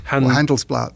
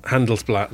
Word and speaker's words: Handelsblatt: Handelsblatt. – Handelsblatt, there we go.